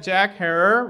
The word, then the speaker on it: Herer: the other thing that Jack Herer rhymes with terror discovers is this film from 1942, made by the U.S.